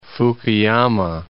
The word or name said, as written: Fukuyama